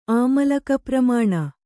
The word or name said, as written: ಆಮಲಕಪ್ರಮಾಣ